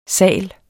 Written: sal

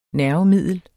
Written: nervemiddel

